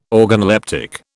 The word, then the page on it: organoleptic: British English